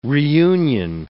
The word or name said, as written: reunion